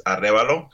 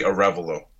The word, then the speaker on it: Arevalo: It's pronounced, the correct way of pronouncing it is Arevalo, because it's a Spanish descent. – Okay. – Or you could just say Arevalo. That also works.